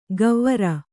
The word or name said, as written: ಗವ್ವರ